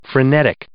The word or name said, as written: frenetic